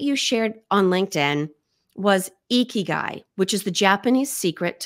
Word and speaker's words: ikigai: The author says ikigai, but other people have a different pronunciation. So yeah. – we're going to go ikigai. If you say ikigai, you're still welcome to listen and we would still want to hear from you, but mm we're following the author's lead.